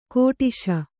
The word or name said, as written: ಕೆಟ್ಟಾಶೆ